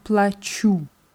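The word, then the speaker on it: плачу: Nur bei der Ich-Form ist es wichtig, die Betonung auf dem U zu haben. Ja, plachu.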